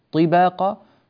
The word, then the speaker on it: طباقا: في قول الله تبارك وتعالى الآية في الخامسة عشرة ألم تروا كيف خلق الله سبع سماوات طباقا، طي عادة يكون هناك خطأ في نطق هذه الطاء فتصير إما أنها طاء مهموسة طباقا طي طي، هذا الصوت الهواء المصاحب لحرف الطاء لا يصح ولا يجوز، وبعضهم ينطقها فلا يكسرها كسرا كاملا ولا كسرا جيدا فيقول طي طباقا طباقا، هذا غير صحيح. طباقا بعدم الهمس في هذه الطاء، وعلاوة على ذلك يجب أن نعتني بماذا؟